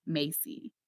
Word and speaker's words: Maisy: But it's spelled so weird. M-A-I-S-Y. Macy.